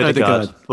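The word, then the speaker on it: Ødegaard: I would start the new guy in central midfield, Odegaard. Did I say that right? I feel like I said that right. – Odegaard. Well, yeah.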